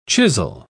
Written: chisel

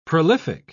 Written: prolific

